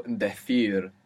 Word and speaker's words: decir: Decir is to tell. Now, so we have hablar, to speak or to talk. And decir is to say or to tell. D-E-C-I-R. So, decir in most of Spain. Decir.